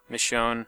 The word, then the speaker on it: Michonne: The other big thing that Telltale announced this month is they're going to be releasing a new Walking Dead game called Michonne. Is that how you say her name? – I think so. – Michonne. – That sounds right.